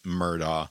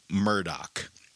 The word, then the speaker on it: Murdaugh: The way that the normal English pronunciation of it would be Alex Murdaugh. His family pronounces it, he has chosen or whatever to pronounce it, Alec Murdaugh, which is an entirely different situation.